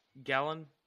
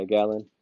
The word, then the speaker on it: Gallen: How do you say his name? Uh, Gallen. – Gallen. – Yeah, Gallen.